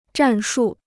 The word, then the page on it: 战术: zhàn shù